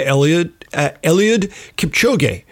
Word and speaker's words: Eliud Kipchoge: like the new marathon record of 201.39 set by Eliud Kipchoge. – Very good pronunciation there.